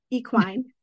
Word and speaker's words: equine: She taught me this word, equine. Did I say it right? – Yes, yes.